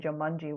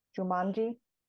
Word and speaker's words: Jumanji: Jumanji. – One more time? – Jumanji. – The first time you said it, you like emphasized a portion of it that I had never heard.